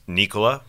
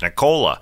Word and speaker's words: Nikola: It was good to hear his name pronounced Nikola by his wife. So we all know it's Nikola because last year I happened to be right in front of Michael Malone. He always pronounces Nicola. it's Nikola for sure.